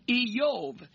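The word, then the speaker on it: Eyov (איוב): For example, the name Job is pronounced this way in Hebrew, Eyov.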